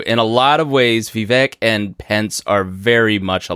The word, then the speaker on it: Vivek: Am I saying Vivek like Vivek? Cake. – Vivek.